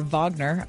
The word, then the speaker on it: Wagner: Number two, the plane carrying Yevgeny Prigozhin, head of the Wagner military group, or Wagner, I think is the, the uh, correct, correct pronunciation, crashed as the result of an assassination plot, which is not a surprise to any of us.